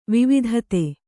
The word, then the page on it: ವಿವಿಧತೆ: vividhate